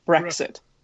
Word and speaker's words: Brexit: Well, it's like the word exit, but with bruh at the front. Brexit.